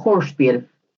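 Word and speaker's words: Hörspiel: Sí, es una palabra alemana, Horspiel.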